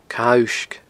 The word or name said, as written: Cadha Uisge